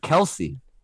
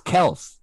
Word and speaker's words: Kelce: And my last take here is I read this week that Travis Kelce claims the pronunciation isn't Kelce, it's Kels.